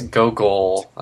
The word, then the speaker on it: Gogol: There's also a point in the – it's in the second episode when I can't – I always pronounce this guy's name wrong and call him Golgo, like he's Golgo 13. – I think it's – yeah, it's like they say Golgo, but it's like goggles or something. – It's Gogol, I think, like G-O-G-O-L.